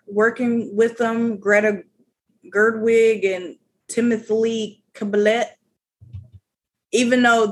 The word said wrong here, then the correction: Chalamet: Chalamet? – Oh, yeah.